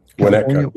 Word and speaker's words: Winnetka: The pronunciation of, is it Winnetka? Is that what it is? – Winnetka, yeah.